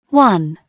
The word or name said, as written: one